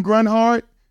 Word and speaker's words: Grunhard: And if you can't pronounce his last name, just think about it. It's Tim G. Runhart. I believe when he was a young man, his high school coach was saying, Tim G. Runhart. – Runhart. – And that's how you spell it. Tim G. Grunhart. Tim Grunhart is Tim G. Runhart.